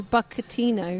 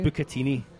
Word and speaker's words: bucatini: My bucatino. – Bucatini.